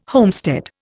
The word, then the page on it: homestead: Indian